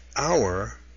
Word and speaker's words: hour: Now, note that hour, H-O-U-R, begins with a consonant, but it has an before it. And the reason why is because the word hour, H-O-U-R, is pronounced like our, O-U-R.